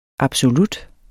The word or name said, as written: ABSOLUTTERNE